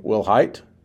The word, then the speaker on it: Wilhite: Wilhite. Did I say it right? – That's good.